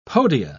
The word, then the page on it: podia: British English